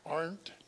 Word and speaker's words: Arndt: How do you pronounce his name? Arndt.